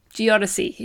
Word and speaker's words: geodesy: I actually had to listen to the podcast again to make sure I was pronouncing that correctly. Geodesy is what it is.